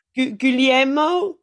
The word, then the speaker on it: Guglielmo: Guglielmo.